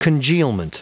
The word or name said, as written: congealment